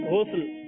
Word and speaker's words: غسل: بتائیے سرگودا والے گلزار طیبہ والوں کو کیا درست کرنا ہے, غسل یا غسل؟ – غسل.